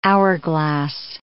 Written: hourglass

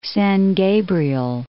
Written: San Gabriel

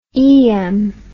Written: eyam

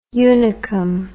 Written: unicum

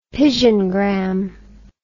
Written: pigeongram

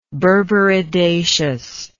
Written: berberidaceous

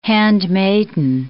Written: handmaiden

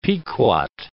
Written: Pequot

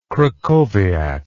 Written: krakowiak

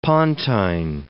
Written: pontine

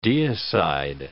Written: deicide

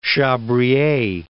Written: Chabrier

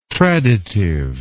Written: traditive

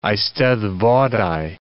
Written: eisteddfodau

